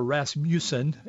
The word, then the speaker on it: Rasmussen: Rasmussen goes on to say, or Rasmussen, as it's correctly pronounced,